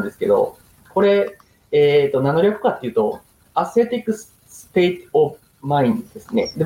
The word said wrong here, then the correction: Celtic: で、僕、ちょっと発音があれなんで、正しい発音は、 Celtic State of Mind。